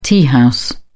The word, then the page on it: teahouse: British English